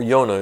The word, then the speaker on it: Yonah: And a- as an example uh, of this idea, we can look at the story of Jonah, uh, or Yonah if you pronounce it in the Hebrew.